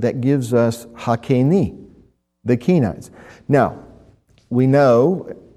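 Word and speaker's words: Hakeni (הקיני): It's He, Kuf, Yud, Nun, Yud. Reading from right to left, once again, He, Kuf, Yud, Nun, Yud. Pronounced Hakeni. Hakeni.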